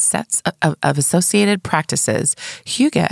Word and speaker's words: hygge: Hygge, pronounced Hygge or Hygge. – I like Hygge.